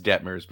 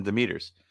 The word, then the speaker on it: Detmers: I know it's Detmers, but I'm gonna be calling him Demeter's.